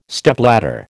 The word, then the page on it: Stepladder: American English